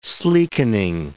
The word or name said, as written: sleekening